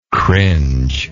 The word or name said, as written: cringe